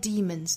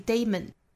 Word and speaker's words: demon: Of our inner demons，最后 demon 这个字母 e 应该是发长音紧音的 e 的发音 demons，所以不要看到这个字母就把它发成了 della，不是 demon，也不是 daemon，应该是 demon。